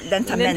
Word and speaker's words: lentamente: lentamente, lentamente, lentamente.